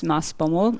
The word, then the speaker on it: Maasbommel: I'm not sure how to pronounce the name of the town. Maasbommel, is that right? – Yes, Maasbommel. Mm-hmm.